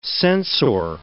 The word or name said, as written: sensor